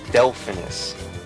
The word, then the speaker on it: Delphinus: A was the Delphinus. D-E-L-P-H-I-N-U-S. For all I know, I might be pronouncing it wrong. – That's it.